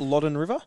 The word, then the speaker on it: Loddon: Loddon River? – Yeah, Loddon River.